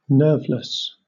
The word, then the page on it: nerveless: British English